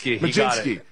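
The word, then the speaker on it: Mlodzinski: Is it Muljinski? Is that how you say his name? – Majinski. – Yes, yeah. Carmen Majinski.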